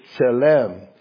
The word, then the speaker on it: tselem: And the word that's used in the Hebrew is, pronounce it, it's tselem.